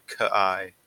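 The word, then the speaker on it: Ka'ai: And it is, I looked at the pronunciation for this guy's name. It was Kai.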